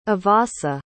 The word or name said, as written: avasa